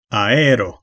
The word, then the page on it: lucht: [lʏχt]; /lʏxt/